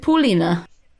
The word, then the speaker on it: Pauliena: And her parents are Dutch and gave her Pauline, but they were presuming that everyone would pronounce it Paulina. But then everyone pronounced it Pauline. And then so she just sticks an A on it so that everyone knows how to pronounce it. – So did she say, though, was it Pauline or Pauline? – She said, because in Dutch it would be Paul. – She said Paulina.